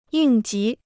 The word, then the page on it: 应急: yìng jí